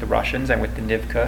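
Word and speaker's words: Nivkh: What main thing I got out of it was the correct pronunciation of Nivka, which is an Aboriginal culture in the Okhotsk region north of Hokkaido. It's spelled N-I-V-K-H. I never knew how to pronounce it. – Nivka.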